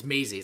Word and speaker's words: Maisie: Macy, she's the cutest. Did I say the name right? – It's Maisie. It's like Daisy.